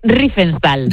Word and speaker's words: Riefenstahl: Pero dímelo tú, ¿es Riefenstahl? – Riefenstahl. – Riefenstahl, vale. – Riefenstahl.